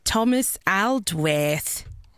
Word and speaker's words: Thomas Aldworth: 6-åriga Thomas Aldworth. Jag uttalar det så för att han är australiensare. – Mm-hmm. – Thomas Aldworth.